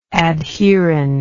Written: adherend

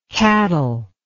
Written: cattleless

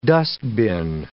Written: dustbin

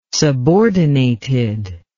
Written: subordinated

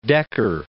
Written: Dekker

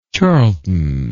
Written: charlton

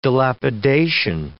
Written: dilapidation

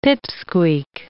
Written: pip-squeak

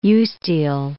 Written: eustele

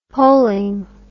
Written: polling

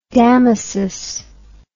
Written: Damasus

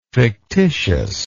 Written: fictitiousness

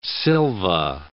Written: silva

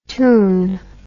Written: toun

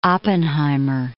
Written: Oppenheimer

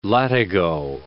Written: latigo

